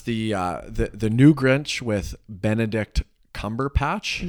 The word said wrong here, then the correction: Cumberbatch: Is that how you say his name? I think so. Cumberbatch. – I think it's Cumberbatch.